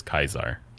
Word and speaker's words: Caesar: Yeah, that's the Roman pronunciation of Caesar is Kaisar.